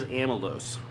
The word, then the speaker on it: amylose: Nope, but it rhymes with maltose, and it has a lot to do with what you just said. And the answer is amylose.